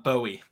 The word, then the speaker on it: Bowie: He's from Bowie. – Bowie. – Bowie. Okay. Yes. I have no idea how to pronounce it. So Bowie. – All good. Bowie.